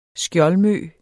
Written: skjoldmø

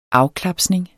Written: afklapsning

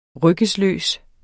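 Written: RYGGESLØST

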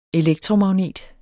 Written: elektromagnet

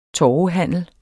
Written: torvehandel

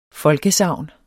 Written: folkesagn

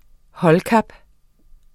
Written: holdkap

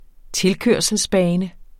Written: tilkørselsbane

